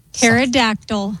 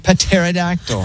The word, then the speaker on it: Pterodactyl: Pterodactyl. – Correct. It's spelled with a P. Pterodactyl.